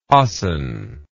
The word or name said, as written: hasan